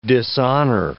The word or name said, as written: dishonor